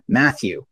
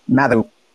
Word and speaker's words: Matthew: You might have noticed that in the clip, and it also didn't pronounce my first name, Matthew, correctly. – Really? – It was like Mathew.